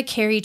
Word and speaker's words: Kerri: I know there's a million ways to spell Kerri, but I just tell people it's like Jello. So instead of J-E-L-L-O, it's K-E-R-R-I.